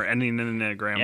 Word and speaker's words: Enneagram: Which I can't pronounce. I might call it Enneagram or Enneagram. – Enneagram.